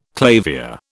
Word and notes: clavier: British English

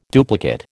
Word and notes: duplicate: American English

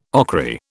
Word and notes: ochry: British English

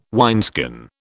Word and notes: wineskin: British English

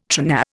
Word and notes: tRNA: Indian

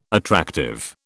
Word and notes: attractive: British English

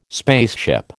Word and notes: spaceship: American English

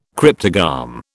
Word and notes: cryptogam: British English

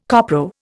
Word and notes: copro: Indian

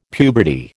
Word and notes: puberty: American English

